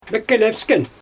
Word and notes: mekelpsken: Xyzyl

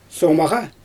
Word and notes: soomaɣa: Xyzyl

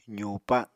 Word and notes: zuupa: apatani

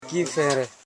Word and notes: kifere: ki.fe.re; Koro

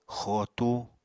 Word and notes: xootu: Apatani

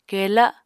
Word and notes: kela: Apatani

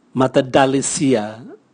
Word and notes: matadalisia: Raga

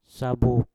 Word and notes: sabub: mpur

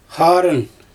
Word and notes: xaarɯn: Xyzyl

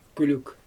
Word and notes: kylyk: Xyzyl

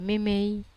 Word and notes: memei: Wamesa

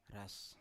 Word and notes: ras: Biak

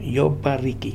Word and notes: Yopariki: Futuna; new form

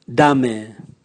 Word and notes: dame: Raga

